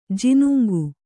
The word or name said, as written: ಜಿನುಂಗು